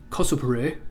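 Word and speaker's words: kosupure (コスプレ): It may be that cosplay, which in Japanese is pronounced kosupure, seriously, and fan magazines and fan magazines are best understood as examples of this process of fictionalization.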